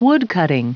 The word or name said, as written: woodcutting